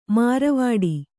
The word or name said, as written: ಮಾರ್ವಾಡಿ